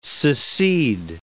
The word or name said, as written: secede